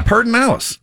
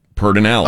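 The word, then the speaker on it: Pedernales: Yeah, Pertinalis. – Well, see? And it says locals in the Texas Hill Country do pronounce it Pertinalis.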